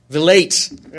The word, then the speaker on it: Vilate: Kimball with the principle of polygamy and that he asks for Violet, his wife – I just pronounced her name wrong. I'm sorry. Violet? Vilate? Vilate.